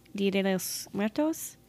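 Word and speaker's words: Día de los Muertos: But, you know, that's, I wonder, the people that celebrate, not the people, but people that celebrate Dia de los Muertos.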